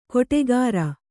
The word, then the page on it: ಕೊಟೆಗಾರ: kannada